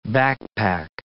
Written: backpack

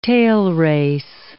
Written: tailrace

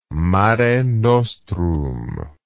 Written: mare nostrum

